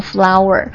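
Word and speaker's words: flour: 比如说面粉，它跟花的读音是一样的 flower， sugar 糖， water 水， syrup 就是糖浆， rice 大米， oil 油， milk 牛奶， wine 酒红酒， coffee 咖啡， happiness 抽象的这种 happiness 抽象名词，幸福快乐， advice 不可数的建议。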